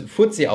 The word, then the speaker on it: FTSE: FTSE oder kurz FTSE ausgesprochen ist ein anderer Indexanbieter, der mit MSCI in Konkurrenz steht.